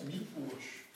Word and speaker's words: Miłosz: Comme c'est un El Barré, ça se prononce Miłosz. Comme ça détaille. Plutôt que de dire Miłosz, dites au moins Miłosz. Parce que SZ se prononce che.